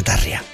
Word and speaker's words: Tarja: Sí, te lo paso y se llama Tarja. Se pronuncia Tarja.